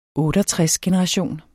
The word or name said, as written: OTTEOGTRESGENERATION